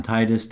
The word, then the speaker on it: Titus: My name, again, is John Titus. That is spelled T-I-T-U-S.